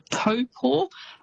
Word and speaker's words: Taupo: but famous spots including Taupo. So quite often you'll hear that said as Taupo. – Yes. – It's almost how it's spelt, but that Taupo is actually pronounced Taupo.